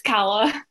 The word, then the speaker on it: Calla: That's not how you say my name. It's Calla.